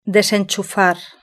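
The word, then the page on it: desenchufar: Spanish (Spain)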